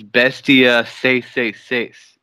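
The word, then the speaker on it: Bestia 666: I'm going to tell you how to say this name. It is not how you would say it. It's Bestia Say Say Say.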